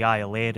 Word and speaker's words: Iolaire: The Iolaire, Gaelic for eagle and so frequently mispronounced as Iolair that it becomes its name, is loaded up quickly with sailors who are keen to be the first foot of their loved ones on New Year's Day.